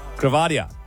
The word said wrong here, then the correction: gravida: I have actually pronounced that one wrong now because you've rattled me. Gravida was what I was trying to say, which is the medical term for how many times a woman has been pregnant.